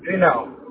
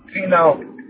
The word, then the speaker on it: Finau: Finau. – What was that? – Finau.